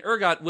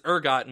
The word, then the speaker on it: ergot: what the fuck is ergot and why does he keep saying it weird? Because it's spelled, just in case you weren't aware, E-R-G-O-T. – Yeah. I've seen it before. Yeah. I just had never tried to pronounce it myself or thought of it. And because ergo is a phrase, it, and, you know, we're thinking of France here, that would stand to reason. So that's why I was chuckling.